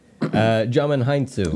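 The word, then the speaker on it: Djimon Hounsou: Digimon, Hansu. – Digimon. Digital Monsters. – Do you know how to say his name? – Jamin Hansu. – Jamin. Hansu. Oh, that's way easier, yeah.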